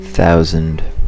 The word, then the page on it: thousand: American English